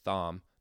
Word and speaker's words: Thom: Now, throughout this, he tells this fascinating story of meeting a contact there, this guy named Thom, not Tom, T-H-O-M, but it pronounced it Thom, who get it right.